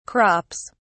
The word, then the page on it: crops: British English